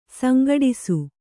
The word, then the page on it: ಸಂಘಟ್ಟಿಸು: kannada